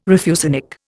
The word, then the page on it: refusenik: Indian